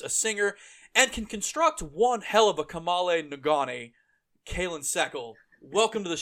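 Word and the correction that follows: kamalen ngoni: I hope I pronounced that instrument right, because it is the most fascinating thing I've ever seen. – Thanks. It's Kamale Ngoni.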